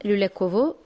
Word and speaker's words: Lülekov: Bu güzel türküyle şimdi Rüyen Belediyesi'nin en büyük köyü olan Lülekov'u, doğru mu söylüyorum telaffuz? – Evet, Lülekov.